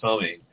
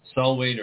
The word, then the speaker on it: Samhain: Solwain or Samhain, depending on how you pronounce spell it. – And what is that? – Solwain is Halloween. – Samhain. I pronounce it Samhain. – Okay. – How do you pronounce it? – There's different ways to pronounce it. Solwain and Samhain.